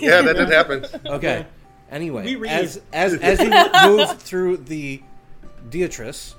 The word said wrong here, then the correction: detritus: the Detritus. It's pronounced Detritus.